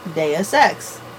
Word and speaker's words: Deus Ex: Yeah, I mean, we really can't do anything else regarding Deus Ex. Jesus. – Deus Ex. Yes, you're saying it right. Welcome to Latin. – D-E-R. – D-E-R. – D-E-R.